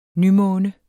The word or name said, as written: nymåne